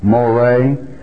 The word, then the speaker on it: Moray: Incidentally, the way she pronounced Murray, did you get it? Moray? That's the way it is in Ireland, okay? That is correct.